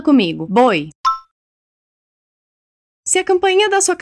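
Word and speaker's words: voy: Apesar de se escrever com V, boi se diz assim, com esse som de B, ok?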